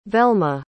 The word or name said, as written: velma